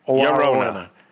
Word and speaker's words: Llorona: Llorona. – My Corona. – It is my Corona, but it's La Llorona.